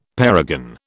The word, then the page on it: parergon: British English